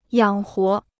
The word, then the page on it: 养活: yǎng huo